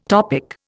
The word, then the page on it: topic: Indian